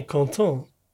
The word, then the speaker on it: Quentin: Ich glaube Quentin. – Nee, Canton. – Bist du sicher? – Ja, er ist Franzose.